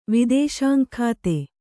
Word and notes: ವಿದೇಶಾಂಗಖಾತೆ: kannada

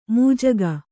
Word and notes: ಮಜ್ಜಿಗೆ: majjige

